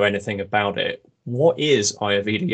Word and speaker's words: Ayurveda: But I'm going to throw a bit of a curveball to start with, because can you explain, and I'm please correct me if I've mispronounced this, did you say it's Ayurveda yoga? – Mm-hmm. Ayurveda.